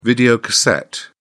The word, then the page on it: videocassette: British English